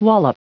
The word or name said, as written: wallop